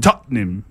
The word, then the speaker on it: Tottenham: decimos, en vez de decir Tottenham, decimos Tottenham. Tottenham. Pues. – Tottenham. – Son dos sílabas.